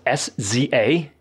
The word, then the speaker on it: SZA: You said the singer's name was S Z A.